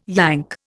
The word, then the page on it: Yank: Indian